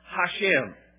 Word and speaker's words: Hashem: One of the names that is commonly used by the Jews is a name pronounced Hashem. It is spelled in English H-A-S-H-E-M, Hashem.